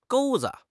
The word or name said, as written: 钩子